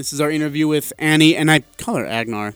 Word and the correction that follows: Agar: That's my bad. Sorry. I tell you, I kept calling her Agnar, and then before our interview started, I was like, hey, how do you say her name? She's like, oh, it's Agar.